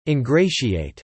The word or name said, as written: ingratiate